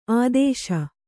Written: ಆದೇಶ

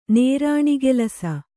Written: ನೇರಾಣಿಗೆಲಸ

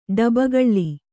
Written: ಡಬ್ಬಗಳ್ಳಿ

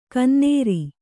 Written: ಗೊನ್ನೂರು

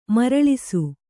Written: ಮರಳಿಸು

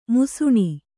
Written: ಮುಸುಣಿ

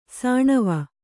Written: ಸಣವು

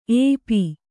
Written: ಏಪಿ

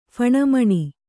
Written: ಫಣಮಣಿ

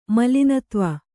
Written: ಮಲಿನತ್ವ

